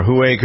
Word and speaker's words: Huawei: Or Huawei could be something that a three-year-old says instead of hooray, so get it.